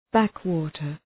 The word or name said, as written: backwater